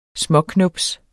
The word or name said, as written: småknubs